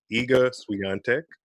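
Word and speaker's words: Iga Świątek: I definitely just mispronounced her name. Her name is pronounced Iga Swiatek.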